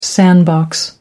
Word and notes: sandbox: British English

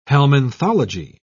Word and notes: helminthology: British English

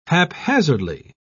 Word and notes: haphazardly: British English

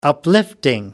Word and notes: uplifting: American English

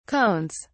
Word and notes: cones: British English

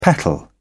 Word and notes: petal: British English

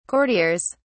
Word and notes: courtiers: British English

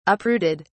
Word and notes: uprooted: British English

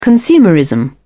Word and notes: consumerism: British English

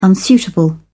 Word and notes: unsuitable: British English

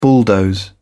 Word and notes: bulldoze: British English

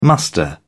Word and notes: muster: British English

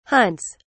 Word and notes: hunts: British English

